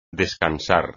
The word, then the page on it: descansar: espagnol